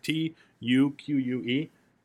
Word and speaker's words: Tuque: T U Q U E, Tuque. Does that sound good? – That's how it's like.